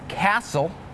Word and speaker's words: castle: Some examples with a silent T are the words listen, the word castle, and the word Christmas.